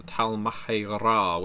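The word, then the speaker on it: Tal'Mahe'Ra: I think V20 True Black Hand, the Book of the Talmahera, which is how you're supposed to pronounce it, was my first writing project for Vampire the Masquerade.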